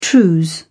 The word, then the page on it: trews: British English